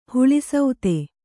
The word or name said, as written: ಹುಳಿಸೌತೆ